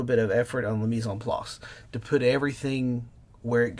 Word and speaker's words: mise en place: It's a French term. I don't speak French, but I think it's pronounced mise en place, which means everything in its place, and do the preparation.